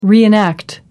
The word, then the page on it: reenact: British English